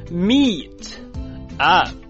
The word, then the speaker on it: meet up: 来，你可以说 meet up， meet up，连起来 meet up， meet up， meet up，注意。如果你是练的美式的发音的话呢，那么这个 meet 的 t 在连读读快的时候呢，一般会浊化成 d d， meet up， meet up，它就可以表示约会碰头或者说是见个面的意思， meet up。